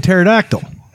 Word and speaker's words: pterodactyl: She pronounces the P in pterodactyl.